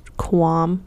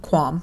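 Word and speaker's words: Khawam: The Guillen family attorney, Natalie Quahom, I hope I'm saying that correctly. – I think I heard in the thing that I listened to, it's Quam. – Quam. – Yeah, Quam.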